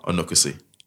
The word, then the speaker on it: Onuekwusi: Justin Chukuma Onuekwusi.